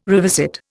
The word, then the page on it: revisit: Indian